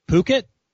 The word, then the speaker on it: Phuket: So there's a city in Thailand that's spelled Phuket. – Phuket. – You pronounce it Phuket?